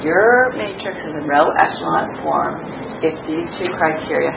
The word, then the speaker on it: echelon: So another kind of weird matrix lingo is that you're going to see is the wording row echelon, the pronunciation is echelon, row echelon form.